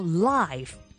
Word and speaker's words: live: 记住，如果你要将佢读成名词嗰个 live，你就要拉长少少嚟读，咁样就唔会同解作生命嘅 life 混淆噶啦。